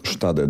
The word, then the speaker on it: Stade: Do 6 osób wzrosła liczba ofiar śmiertelnych strzelaniny w ośrodku dla młodocianych matek w niemieckiej Dolnej Saksonii, dokładnie w miejscowości Stade, jeśli dobrze wymawiam. – Stade, dokładnie. – Stade.